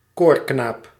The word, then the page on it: innocent: American English; [ˈɪ.nə.sənt]